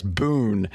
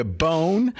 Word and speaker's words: boon: Boon. it will be a bone, but don't say it like that. Say boon.